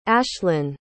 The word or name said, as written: ashlynn